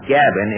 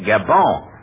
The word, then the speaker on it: Gabin: Oh, not Gabin. It's Jean Gabon. – Gabon? – Yes, Jean Gabon. You pronounce it through the nose, you see.